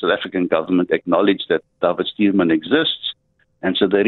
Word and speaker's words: David: A young man from the Eastern Cape called David, as it's pronounced in Afrikaans, David Stuurman.